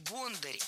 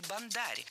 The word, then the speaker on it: бондарь: Кто же такой бондарь или бондарь? Ударение в этом слове двойное.